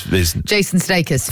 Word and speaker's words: Sudeikis: Jason Sudeikis.